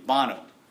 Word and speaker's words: Bono: Is it Bono, thank you. Bono.